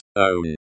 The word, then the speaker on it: Он: On. On means he. This is the masculine nominative form of the pronoun. Pronounce it as own.